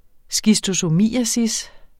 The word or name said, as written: schistosomiasis